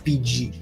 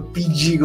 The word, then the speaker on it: Pidgey: signora nella pubblicità di Pokémon Go si chiama PG e quindi ho fatto PG come pronuncia pubblicitaria